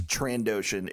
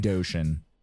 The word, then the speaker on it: Trandoshan: I always thought there was a soft S after the N. – It's Trandoshan. It's going to take time. – Trandoshan.